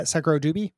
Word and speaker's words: SekiroDubi: Uh, it is spelled uh, SekiroDubi S E K I R O D U B I. Uh, yes. I say uh, SekiroDubi is how mm-hmm.